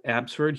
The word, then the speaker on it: Abbotsford: And then you play for Abbotsford Heat.